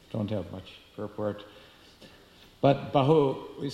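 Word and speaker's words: Bahu (बाहु): So that Bahu is different, that's short A, Bahu, Bahu.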